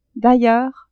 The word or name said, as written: d'ailleurs